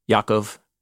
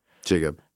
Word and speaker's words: Yaakov: And these are the names of the sons of Israel, the ones who went to Egypt with Yaakov. – Jacob. – Jacob, yeah. Sorry, I'm trying to use Hebrew pronunciation in my translation.